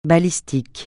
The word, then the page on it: balistique: francais